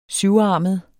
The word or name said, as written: syvarmet